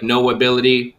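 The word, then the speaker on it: Know Ability: So it sounds like No Ability, but it's spelled K-N-O-W ability.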